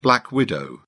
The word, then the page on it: black widow: British English